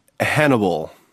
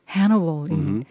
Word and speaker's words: Hannibal: 영어로 발음이 어떻게 됩니까? – Hannibal. – Hannibal.